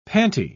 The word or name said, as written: pantie